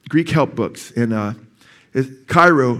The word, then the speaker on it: chairo: It's chairo. chairo, C H A I R O is how you say it in English.